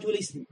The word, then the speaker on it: الاسم: علامات الاسم، لأن كلمة الاسم همزتها همزة وصل. فما نقول علامات الاسم، لا علامات الاسم، علامات الاسم لأن همزة همزة وصل، فلا تنطق إذا في الوصل، لا تنطق، تسقط في الدرج.